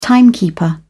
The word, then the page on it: timekeeper: British English